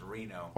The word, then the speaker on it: Reno: I think it's pronounced Reno.